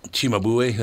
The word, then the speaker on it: Cimabue: Cimabue. – Cimabue, that's how you say it.